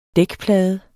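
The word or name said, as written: dækplade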